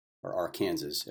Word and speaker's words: Arkansas: I am in Arkansas, as it should be pronounced properly.